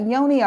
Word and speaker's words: yoni: Am I saying it right? Yoni or Yanni? – Yummy yoni. – Yummy yoni. Yes.